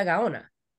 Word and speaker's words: Gaona: It's Ana María Gaona. But when people say my name, they're like Ana Gaona, Ana Ganoa. I get it. It's not a common name, but it's just the sounds of the vowels, right? – Yeah.